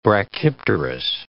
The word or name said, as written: brachypterous